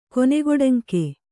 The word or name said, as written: ಕೊನೆಗೊಡಂಕೆ